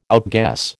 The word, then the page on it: outguess: American English